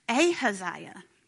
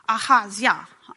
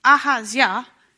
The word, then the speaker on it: Ahaziah: That's not how you say it. It's something like, you know how you have to do something in your throat, like aha, like Ahaziah. I don't know, something like that.